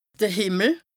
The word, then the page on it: de Hìmmel: Bas-Rhin; Strasbourg